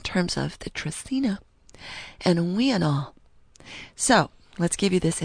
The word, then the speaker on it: uinal: Now, it's we and all, which is spelled U-I-N-A-L, and pronounced... We and all.